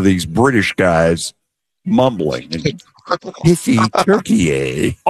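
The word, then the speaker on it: Türkiye: It's Turkey A instead of Turkey.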